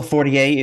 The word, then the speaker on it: Fortier: Yes, as also Fortier, if you speak Fortier.